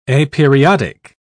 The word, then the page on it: aperiodic: British English